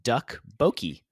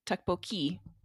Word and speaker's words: Tteokbokki: New York Times has to be, they say pronounced Tteokbokki. – Tteokbokki.